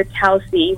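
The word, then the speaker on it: Tausi: And if you're sitting in a chair and you have your Towsy, which is the name of my company, Towsy Brands. – Spell that? – T-A-U-S-I. T-A-U-S-I.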